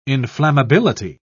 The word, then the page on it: inflammability: British English